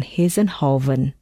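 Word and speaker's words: Hissenhouwen: Ayu van Hiesenhoven. Yes, that's right. Let me spell it. A-Y-U V-A-N H-I-S-S-E-N H-O-U-W-E-N.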